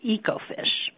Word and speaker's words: Ecofish: So we have two different, and actually, just to start out, it's called, we pronounce it Ecofish. So Oh, okay. – as opposed to echo, but so Ecofish.